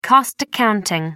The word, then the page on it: cost accounting: American English